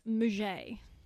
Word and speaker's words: Mudgett: It's Mudgett. – It's pronounced Mudgett, darling.